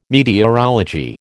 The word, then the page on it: meteorology: American English